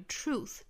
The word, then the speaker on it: truth: 最后半句 must be the truth, must 尾部特省掉 be the truth, 最后一个词我们再讲一下，就是这个 truth 这个词大家不要把它读成了触触角的触那个音哈，一定要注意这个辅音 r, 唇形还有舌头的一个位置，在发 r 的时候